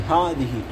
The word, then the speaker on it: هذه: هو بالأصل باللفظي ها تلفظ. هذه تلفظ لكن لا تكتب.